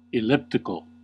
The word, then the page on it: elliptical: American English